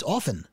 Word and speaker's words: often: There's a T in the middle. I said, yeah, but it's silent. It's often.